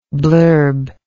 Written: blurbist

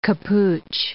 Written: capuche

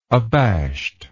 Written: abashed

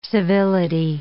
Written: civility

